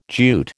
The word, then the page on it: Jute: American English